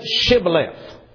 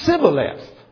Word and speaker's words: Shibboleth: Say Shibboleth. And those Ephraimites, if he said Sibboleth, you see, he couldn't frame it. He couldn't say it right.